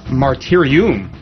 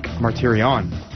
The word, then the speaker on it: Martyrion: In Latin, it would be pronounced Martyrium, but in Greek, Martyrion.